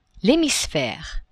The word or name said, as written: hemisphere